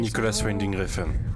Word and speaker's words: Nicolas Winding Refn: Nicolas Winding Refn.